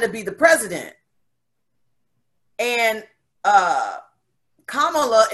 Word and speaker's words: Kamala: And I don't want to pronounce her name wrong. You said Kamala. Kamala. Yeah.